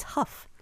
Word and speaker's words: Hough: It's Huff. – Huff. Okay, thank you. H-O-U-G-H. It's like, whoa. – It's like rough. So it's not ho, like dough.